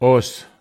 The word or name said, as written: os